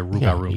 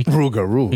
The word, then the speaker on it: Rougarou: Am I saying it right? Rougarou? – You could just say Rougarou. – Yeah, Rougarou. – Yeah, because you can't roll your tongue like I can. Rougarou.